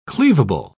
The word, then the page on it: cleavable: British English